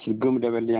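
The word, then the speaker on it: Gumudavelli: It's Gumdevelli.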